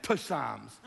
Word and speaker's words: Psalms: Should be now Psalms, silent P.